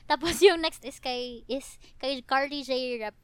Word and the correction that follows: Carly Rae Jepsen: Car, wow, Carly Rae Jepsen.